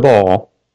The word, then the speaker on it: Baal: I have kept for myself 7,000 men who have not bowed the knee to Baal. Or Baal, some people pronounce it.